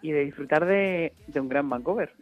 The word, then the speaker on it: Vancouver: Agoney se llama Vancouver, o ¿cómo lo dices tú? ¿Cómo lo pronuncias? – Así mismo es, Vancouver. – Vancouver.